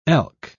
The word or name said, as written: elk